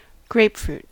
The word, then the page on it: grapefruit: American English